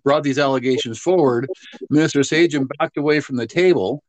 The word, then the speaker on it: Sajjan: And then even when Harjit Sajjan, and I've heard it pronounced Sajjan, so I'm going to go with that now.